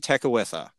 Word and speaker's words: Tekakwitha: Saint Kateri Tekakwitha. So, and I know I'm pronouncing that correctly because a friend of mine has her as her confirmation saint.